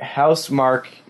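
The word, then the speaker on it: Housemarque: Okay, Housemark, but it's spelled M-A-R-Q-U-E. – Yes, they're Finnish.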